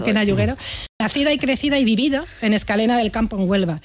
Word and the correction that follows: Escacena: Escacena. Mira que te lo he dicho mal varias veces, ¿eh? Por esta. Escacena.